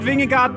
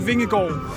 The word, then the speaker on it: Vingegaard: Not, not, not Vingegaard, but Vingegaard.